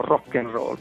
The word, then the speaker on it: Rock and Roll: Il titolo Rock and Roll, l'unica cosa scritto appunto, per chi ascolta magari non vede la copertina del CD, è scritto come si pronuncia in italiano, Rock and Roll, con l'accento sulla ultima O.